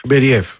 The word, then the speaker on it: Μπεριέφ: πού τονίζεται πρώτα απ' όλα για να το λέμε και σωστά. – Νομίζω το λένε Μπεριέφ, λοιπόν. – Νομίζω τώρα είναι Μπεριέφ. Από ό,τι έχω διαβάσει, τέλος πάντων, έχω δει να τονίζω στον Μπεριέφ.